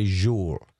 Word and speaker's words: Joel: It's spelled Joel, but he's Swedish, so we say Joel.